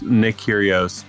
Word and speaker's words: Nick Kyrgios: Karen Khachanov bests Nick Kyrgios in a five-setter at U.S.